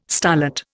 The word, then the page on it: starlet: Indian